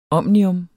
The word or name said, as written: omnium